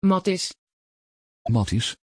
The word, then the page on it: Mattiz: Dutch